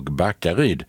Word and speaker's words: Backaryd: Jag såg att det är ett på Riksväg 27 mellan Hallabro och Backer. Hallabro och Backaryd, betoningen där, det ställer till lite med framkomligheten.